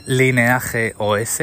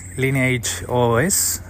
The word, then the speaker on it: Lineage OS: La más popular y que además yo usé en el pasado se llama Lineage OS en, español, es que pronunciado en castellano. Lineage OS en inglés.